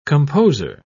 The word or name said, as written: composer